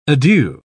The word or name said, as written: adieu